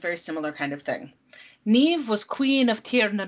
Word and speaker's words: Niamh: This is a story of Niamh and Oisín. Not pronounced, not written to be pronounced like that at all. I had to Google that.